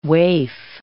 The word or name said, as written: waif